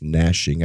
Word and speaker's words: gnashing: Now, just for the record, I know it's gnashing. I know the G is silent.